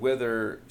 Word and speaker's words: Wither: Because otherwise I would have said Wither sounds like Mother from Danzig when you say it right.